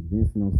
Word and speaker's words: Vincent: which is Vincent O'Sally.